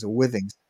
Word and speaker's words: Withings: Withings.